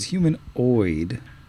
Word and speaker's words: humanoid: It's humanoid. – Yeah. Unfortunate. – Humanoid. – That's all right. We'll accent on the oid.